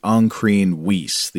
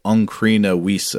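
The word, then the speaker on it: Ancrene Wisse: The a text about how to become an anchoress came up, and I called it the Ancrene Wiese, the A-N-C-R-E-N-E, and then W-I-S-S-E. But Simon says the Ancrene Wiese popped up and you pronounced both words with a silent final E. The consensus pronunciation would have those final vowels voiced rather like a German speaker might pronounce Porsche, I think. So I believe it would be Ancrene Wiese, I guess, if Simon is correct.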